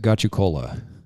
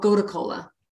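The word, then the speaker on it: gotu kola: How do you say it? I've always said it, got you cola. – Oh, gota kola. – Gota kola. Okay.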